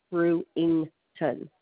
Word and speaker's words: Brewington: It's Brewington. B as in boy, R as in Robert, U as umbrella, then INGTON, Brewington.